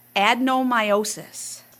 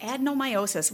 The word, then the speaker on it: adenomyosis: Adenomyosis. – Perfect. – Yay. – You said it perfect. – Adenomyosis.